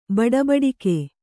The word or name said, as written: ಬಡಬಡಿಕೆ